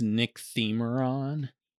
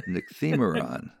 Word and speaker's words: Nycthemeron: It's called Nycthemeron. Am I pronouncing that right? – I think it's Nycthemeron. – Thank you, Mr. Accent the Syllable. Nycthemeron.